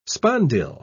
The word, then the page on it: spondyl: British English